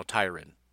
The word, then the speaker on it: Tyrann: and they say you're supposed to say it like Byron, but it's with a T. That's what Google says, so his name is now Tyrann.